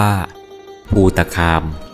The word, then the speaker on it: ภูตคาม: สวัสดีครับพบกับรายการคำวัดวันนี้ขอเสนอคำว่าภูตะคาม